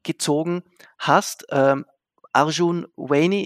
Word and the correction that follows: Arjun Wani: ich hoffe, ich habe es richtig Arjun Wani, ja. Arjun Wani, gut, ja.